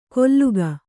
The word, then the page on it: ಕೊಲ್ಲುಗ: kolluga